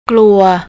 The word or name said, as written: kluy